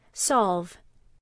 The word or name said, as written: solve